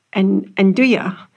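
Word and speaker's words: 'nduja: Antonio from Lanama, he pronounced it Nduja, because that's how they pronounce it in Italy.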